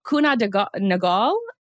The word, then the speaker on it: Kuehne + Nagel: It's Kuehne + Nagel is how you pronounce this company name.